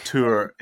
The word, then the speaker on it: Troyes: And these would lead to the Treaty of Troyes. Did I say that right? And the first signature on the treaty was Charles's. And it was Tour. It's just tour. Like you're saying tour.